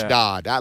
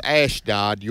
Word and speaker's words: Ashdod: But it's Ashdod. Let me go back to the Old Testament. Now I'll go back to the Ash. It is Ashdod.